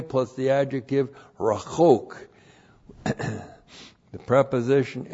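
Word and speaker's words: rachok (רָחוֹק): And then it's that's modified by the adjective rachok, which looks like this. R-A-C-H-O-Q, rachok.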